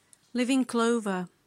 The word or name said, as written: live in clover